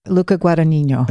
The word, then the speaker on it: Luca Guadagnino: Luca Guadagnino.